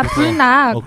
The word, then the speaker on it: 불낙: 허재 전 감독님이 발음이 좀 안 좋으신데 이게 불락이야? 어 이게 불락이야 하는데 불락 있잖아요. 불고기 낙지. – 네, 아 불낙.